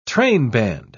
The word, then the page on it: trainband: British English